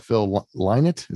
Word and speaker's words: Phil Lynott: And yeah, I mean, due to the untimely death of Phil Lynott, is that how it's pronounced? – Yeah, that's how I say it.